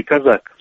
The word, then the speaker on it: Козак: Но поскольку Дмитро Миколаевич, так сказать, чистокровный хохол, то правильно будет произносить в малороссийской транскрипции «Казак».